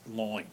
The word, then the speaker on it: line: We know further that line was pronounced loin. It was sort of a Cockney pronunciation. So Pope said, and 10 low words oft creep in one dull loin, while expletives their feeble aid do join.